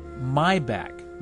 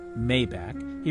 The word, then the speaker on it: Maybach: Finally, also regarding the Unger report, Kirk Seaman in Ann Arbor notes the luxury auto is pronounced Maybach, not Maybach.